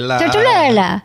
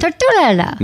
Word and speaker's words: Tortorella: Tortorella. That's how you say it, right? – That's it. – Tortorella.